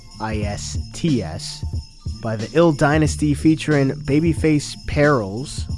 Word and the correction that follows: Perilous: Actually, no, I said that wrong. Babyface Perilous and Casablanca with cuts by DJ Can.